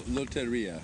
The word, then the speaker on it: Lotteria: Lotteria, Lotteria.